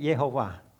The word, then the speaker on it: Jehovah: You pronounce it Jehovah. – 但是每个英文圣经呢，在过去400年写下上帝的名字都是耶和华。 – Okay, in Hebrew there is not a hard J, it's a soft J like a Y.